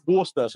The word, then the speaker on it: Worcester: It looks like Worcestershire. – Yeah. Yes. – But it's actually Worcester.